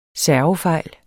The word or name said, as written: servefejl